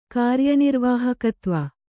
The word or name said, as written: ಕಾರ್ಯನಿರ್ವಾಹಕತ್ವ